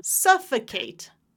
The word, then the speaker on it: suffocate: So the first one is three syllables. Oh, the second one is five. – Ready? So first one, da da da, suffocate. Suffocate. Perfect. Suffocate.